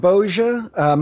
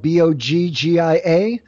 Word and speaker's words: Boggia: I am on all the usual things as Jim Boggia. My last name is spelled Boggia.